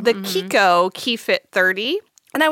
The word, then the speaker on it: Chicco: I was just casually like thinking about the car seat we have, which we had the Kiko, spelled C-H-I-C-C-O, which I always thought was Chico until you taught me the way.